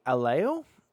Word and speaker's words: Aiello: Danny Aiello? It's A-I-E-L-L-O. Aiello? – A-I-E-L-L-O? – Yeah. – Aiello. – Aiello? Okay. – Aiello? – Danny Aiello. Have you ever seen the movie Jacob's Ladder? – No.